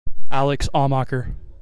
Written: Alex Amacher

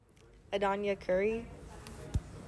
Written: Adonijah Currie

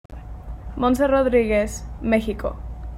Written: Montse Rodriguez

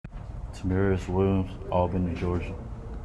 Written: Temirez Williams Jr.

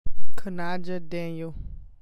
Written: Kanija Daniel

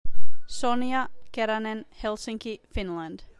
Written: Sonja Keranen

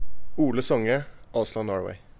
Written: Ole Songe

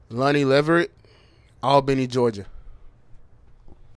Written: Lonnie Leverette

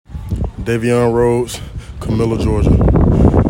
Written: Davion Rhodes

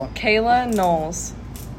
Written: Kayla Knowles

